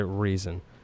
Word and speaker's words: Rezin: Where did you get a nickname like Resin anyway, if you don't mind me asking? – Actually, we pronounce it Reason.